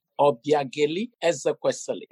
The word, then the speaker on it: Obiageli Ezekwesili: It's quite an honor to have you on my podcast, ma'am, Obi Gaeli Ezekwesili. Did I pronounce that correctly? – Call me Obi. Obiageli Ezekwesili.